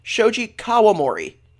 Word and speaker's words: Shoji Kawamori: We're gonna have some misinformation here that I don't want to spread, but, first of all, it is Shoji Kawamori. That's how you say it.